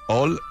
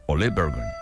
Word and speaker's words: Ole Bergan: Principios del siglo XX, el noruego, ¿cómo se pronuncia? Ol o Ole Bergan. Así dice, Ole Bergan.